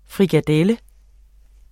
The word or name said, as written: frikadelle